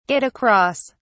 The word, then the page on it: get across: British English